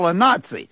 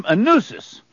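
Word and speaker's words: Anuzis: And he said, I pronounce his last name Saul Anunzi. Well, it's A-N-U-Z-I-S. But Jeff just tried the number to make sure it works, and it did work. And he pronounces his name Anunzis.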